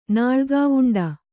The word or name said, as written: ನಾಳ್ಗಾವುಂಡ